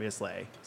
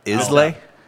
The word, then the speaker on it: Islay: Islay? – Islay. – I thought it was Islay. – I thought it was too. – Is it? Well, I've been saying it wrong this whole time.